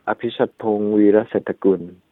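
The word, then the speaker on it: Apichatpong Weerasethakul: In the Thai way, you know, it's very low sound. It's called Apichatpong Virasatakul.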